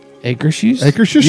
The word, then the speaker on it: Akershus: I'm either going to, I always pronounce this wrong, but Osherhouse? – Akershus? – Yes, see? I'm either going to Akershus for the princesses and those type of things, or I'm going to go to, what is the restaurant Garden Grill? – No, Grand Fiesta.